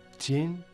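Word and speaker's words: Тень (天): Тень, небо, произносится в первом тоне.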